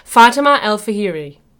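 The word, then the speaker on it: Fatima al-Fihri: Fatima Al-Firia. Oh my gosh, I said it wrong. The last one. I did it well the whole time. Fatima Al-Fahiri.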